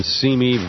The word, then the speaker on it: Simi: About an hour from now, the remaining Republican candidates for president will gather at the Reagan Library in Simi Valley. It's spelled S-I-M-I, but we should change it to S-E-M-E-Y. No, S-E-A-M-Y, sorry.